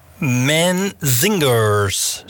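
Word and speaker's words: Menzingers: En daarvoor, ik heb het even opgezocht, de Manzingers. Zo spreek je het uit.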